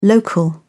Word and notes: local: British English

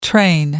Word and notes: train: American English

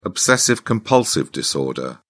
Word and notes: obsessive-compulsive disorder: British English

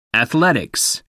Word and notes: athletics: American English

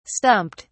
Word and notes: stumped: British English

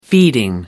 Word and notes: feeding: British English

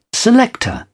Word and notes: selector: British English

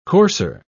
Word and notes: courser: British English

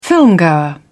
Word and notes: filmgoer: British English

